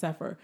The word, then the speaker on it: Cepher: But it looks like Sefer. C-E-P-H-E-R. But yeah, it's pronounced Et Sefer.